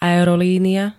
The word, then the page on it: aerolínia: [a-ero-]